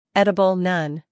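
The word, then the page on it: edible: uk ˈedəb(ə)l; en-us ˈedəb(ə)l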